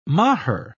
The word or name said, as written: macher